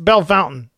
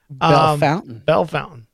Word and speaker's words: Bellefontaine: He says, hey, it's Jake from Bellefontaine, pronounced Bellefountain. Okay. Sorry. I'm not French. So Bellefountain.